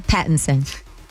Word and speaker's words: Pattinson: Pattinson.